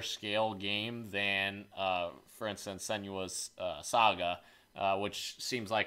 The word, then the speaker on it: Senua's Saga: The Hellblade team is making Senua's Saga.